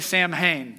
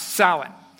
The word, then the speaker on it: Samhain: Now, I know in Halloween 2 and some heavy metal albums, they say Samhain, but that's not actually how that word's pronounced. Pronounced Samhain.